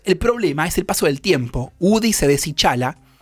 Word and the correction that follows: deshilacha: Deshilacha. – Deshilacha, lo dije mal.